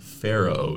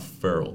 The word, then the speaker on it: Pharaoh: Faroe dog, not feral dog. – Like an Egyptian pharaoh.